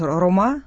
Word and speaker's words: рома: Ну, цыгане или рома называют в Европе, а у нас в России чаще говорят рома, поскольку это на цыганском языке правильное ударение.